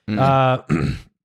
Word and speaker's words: Rotenburg: So on March 9th in 2001, the two met at Frankie's home in the small town of Rotenburg, or I don't know how to pronounce that. R-O-T-E-N-B-E-R-G. – Rotenburg? – Rotenburg.